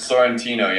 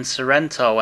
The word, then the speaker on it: Sorrentino: Sorrentino, is that correct? – Sorrentino, yeah. It's been muddled a few times, but you nailed it. – Yeah, I guess people have been saying Sorrento and stuff like that, right? – Yeah, no, I'm not a Kia vehicle.